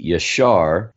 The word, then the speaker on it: yashar: So if you were going to pronounce it correctly, according to the Hebrew, it would be Yashar.